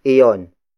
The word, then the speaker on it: iyon: Then the next word is iyon. Iyon. Iyon. Ion is spelled as I-Y-O-N.